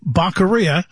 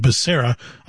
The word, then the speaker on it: Becerra: Here we have, Baccaria. I always like to call him that because of, you know, the former president's mispronunciation of his name, Becerra, Javier Becerra.